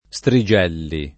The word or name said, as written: Strigelli